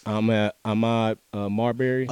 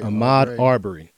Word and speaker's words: Ahmaud Arbery: Ahmaud Arbery. Did I get his name wrong? – Yes. – See, I'm fumbling the ball again. Ahmaud Arbery. So okay.